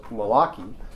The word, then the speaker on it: Malachi: So this month we're going to begin the book of Malachi, and this is obviously a Hebrew name, as I've mentioned previously, that's actually pronounced Malachi, and it's derived from a Hebrew expression meaning to dispatch as a deputy or officer or a messenger.